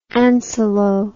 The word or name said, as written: ancylo